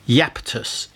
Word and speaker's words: Iapetus: And this album is called, this is a difficult one to pronounce, Iapetus is how I'm pronouncing it.